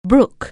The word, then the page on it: brook: anglais